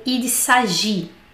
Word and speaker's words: il s'agit: Ensuite, sempre vejo essa expressão sagitilha ou il s'agit. O que, que é isso? Bom, primeira coisa, eu tenho que chamar a atenção de vocês, claro, para essa expressão que não falamos, não pronunciamos o T quando.